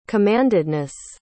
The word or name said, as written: commandedness